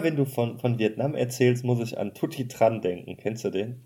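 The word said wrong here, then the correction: Tutty Tran: Ja, Tutti Chan heißt der übrigens. – Oh, Entschuldigung. Wie heißt der? Tutti Chan? – Ja. Also das TR spricht man Ch aus.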